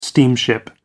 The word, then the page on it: steamship: American English